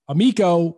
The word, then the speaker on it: Amico: The guest of the show, Tommy Tallarico, you are the CEO of Intellivision, and you guys have a product, Amico, which rhymes with Tallarico.